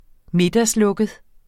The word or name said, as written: middagslukket